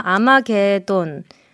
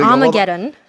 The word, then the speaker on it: Armageddon: 영화 아마게돈. 예, 아니, 그 Armageddon. – 영어 발음으로 해요. 개돈 얘기하지 마시고.